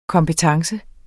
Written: kompetence

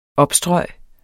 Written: opstrøg

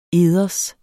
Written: eders

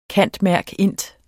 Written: cand.merc.int.

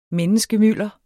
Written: menneskemylder

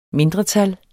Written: MINDRETALLET

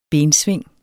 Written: bensving

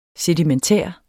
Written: sedimentær

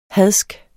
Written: HADSKE

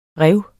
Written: rev

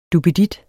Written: DUPPEDITTEN

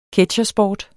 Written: ketsjersport

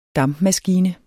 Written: DAMPMASKINE

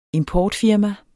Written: importfirma

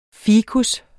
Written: ficus

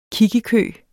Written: kiggekø

